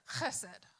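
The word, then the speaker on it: Chesed (חסד): It helps if you spit a little when you say it. Chesed. I'm not sure I even say that right. I say it more like a French R than a Hebrew CH, but or KH.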